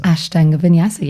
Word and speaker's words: Ashtanga Vinyasa: And first of all, did I pronounce that right? Ashtanga Vinyasa. – Ashtanga Vinyasa. Yes, you did.